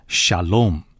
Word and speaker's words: shalom: 平安这个词，希伯来的原文念作 shalom。